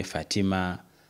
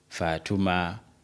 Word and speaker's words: Fatima: Bagikyusakyusa mu pronunciation naye tugenda kulaba gye liva. Ya kusanga balina nga bw'okikonye Fatima, Fatuma, Fatma, waliwo abateekako sukuuni ne wasigadde.